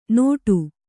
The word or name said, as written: ನೋಟು